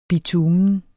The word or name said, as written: bitumen